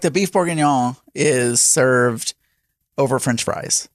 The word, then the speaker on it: Bourguignon: How do you say that? Bourguignon? – Basically like that.